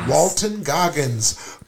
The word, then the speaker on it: Walton Goggins: Walton Goggins.